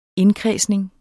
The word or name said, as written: indkredsning